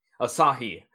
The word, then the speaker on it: Asahi: You have all of the Cyber Agent and TV. How's that pronounced? – Asahi.